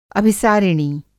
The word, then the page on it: अभिसारिणी: Hindi